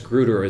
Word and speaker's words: Grutter: Up until recently, I was pronouncing it Grutter. But, right, and it is Grutter is the way we always learned it.